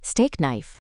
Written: steak knife

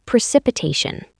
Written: precipitation

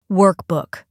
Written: workbook

